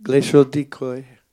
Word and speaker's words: Glacial Decoy: Comment on Glechodicoy. – Voilà, c'est mieux. Hein, c'est tout de suite mieux.